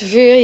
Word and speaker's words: VUUR: So I definitely want to talk about the new band, VUUR, right? Or do you pronounce it differently? – It's VUUR, yeah, it's perfect.